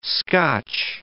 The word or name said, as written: Scotch